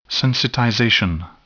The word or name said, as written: sensitization